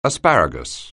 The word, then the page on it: asparagus: anglais